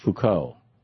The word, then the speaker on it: Foucault: If stalkers or rapists or people who are, Sue says it's pronounced Foucault.